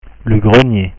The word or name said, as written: le grenier